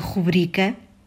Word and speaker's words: rubrica: Portanto, a palavra rubrica tem o acento tônico na penúltima sílaba bri e escreve-se sem qualquer acento gráfico, seja qual for o seu significado, assunto, apontamento, programa televisivo ou radiofónico e ainda assinatura abreviada.